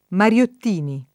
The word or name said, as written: Mariottini